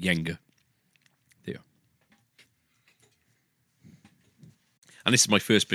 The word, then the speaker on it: Jenga: Interesting fact, I pronounced it Jenga until the age of about 21 when somebody corrected me and said it's Jenga, and I didn't believe them until...